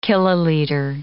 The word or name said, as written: kiloliter